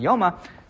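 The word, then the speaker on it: Yuma (יומא): So Maseches Yuma, or some people pronounce it Yoma.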